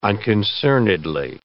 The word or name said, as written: unconcernedly